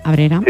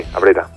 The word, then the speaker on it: Abrera: Abrera, lo digo bien, ¿verdad? – Abrera. Sí, sí, sí, Abrera.